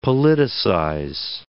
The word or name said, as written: politicize